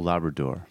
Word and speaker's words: Labrador: Labrador. Have I pronounced that properly? – I believe you did. Raul Labrador.